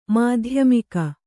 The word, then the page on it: ಮಾಧ್ಯಮಿಕ: Kannada